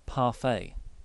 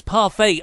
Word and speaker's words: parfait: I want to say parfait. – That's correct. – Good. Because it's spelt like the French just trying to, so they can laugh at everyone else reading their words phonetically. Parfait. Or yeah, parfait. – No. Yes, it's parfait.